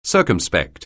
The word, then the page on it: circumspect: anglais